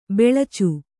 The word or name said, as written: ಬಿಳಿಚ